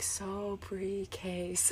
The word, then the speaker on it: Sobrique: I was like, Sobrique, That's right.